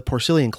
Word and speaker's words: Porcellian: Now, if you look at the name of the club, Porcellian, while it's spelled the same, the root pork, P-O-R-C, in the Porcellian Club, which is pronounced with a soft S in that case, is a very different word root from the P-O-R-C, pronounced with a hard C, in, say, Porkfest, which I attended back in the middle of the summer.